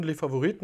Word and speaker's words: Les Favorites: Ich habe schon gesagt, ja, Leiter und Dirigent auch vom Vokalensemble Les Favorites in Rastatt. Habe ich es richtig ausgesprochen oder wie? – Ja, Vokalensemble, also französisch, genau, inspiriert und Les Favorites nach unserem schönen Barockschlösschen der Markgräfin, genau.